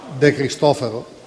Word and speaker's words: De Cristofaro: De Cristofaro? Dipende. Dipende. L'accento non c'è. L'accento non. – Ministro, glielo posso suggerire io, era corretto con la.